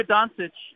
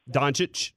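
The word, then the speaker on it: Dončić: By the way, your boy Luka Doncic is. Can we get a referendum on this? How are you guys pronouncing his last name? – Doncic.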